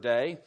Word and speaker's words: dei: He reminds them that he had prophesied that he would be crucified and raised on the third day. But notice the word must be fulfilled. That word spelled in English is D-E-I, okay? It's pronounced day.